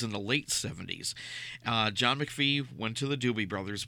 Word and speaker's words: McFee: John McVie, M-C-F-E-E.